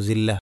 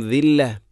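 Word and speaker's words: ദില്ല (ذلة): തർഹഖുഹും സില്ല, ദില്ലക്ക് പകരം പലപ്പോഴും സില്ല, ഇതൊക്കെ ശ്രദ്ധിക്കണം. തർഹഖുഹും ദില്ല.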